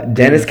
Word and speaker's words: Denys: How would you pronounce this name? It's spelled D-E-N-Y-S. Would it just be Dennis? – Okay, D-E-Y-S. – No, D-E-N-Y-S. Dennis? – Dennis? – Yeah. – Dennis Cowan and Michael Davis.